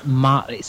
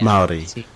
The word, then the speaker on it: Maori: Maori. – See, I cannot roll my R's, and it has a rolled R in it. – It does. Yeah, Maori. – Although if you at least say Maori, it's at least closer than many Americans get.